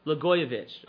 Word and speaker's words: Blagojevich: And as you probably know, maybe you've seen in the news, dethroned Illinois Governor Rod Blagojevich.